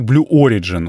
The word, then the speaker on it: Blue Origin: Есть такая компания, как Blue Origin. Ударение на первый слог, э, это я уже понял.